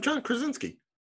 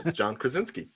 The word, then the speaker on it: Krasinski: How do you screw up John Krasinski? – Very easily. – John Krasinski.